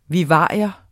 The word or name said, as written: vivarium